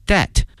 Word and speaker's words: debt: Lee now has paid back most of his debt，债务这个词，它的拼写呢是 D-E-B-T，但是哈、有一点哈、在考试的时候，特别在变音的时候，经常会考大家这个 B 啊，这个 B 呢是不发音的，它的发音呢是 debt，但是呢拼写里面会有这个字母 B，所以同学们一定要小心了。